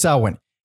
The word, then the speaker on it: Samhain: Danzig says Samhain. – Yeah. But in interviews and when discussing the band, it's Sam Hain.